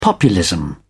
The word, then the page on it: populism: British English